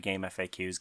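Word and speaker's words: GameFAQs: I say game facts. Is it game facts? Does it sound bad to say game facts? – I have always said game FAQs. Game facts sounds to me like when people pronounce SNES as a word.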